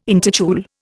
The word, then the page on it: intitule: Indian